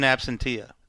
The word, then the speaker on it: absentia: In absentia.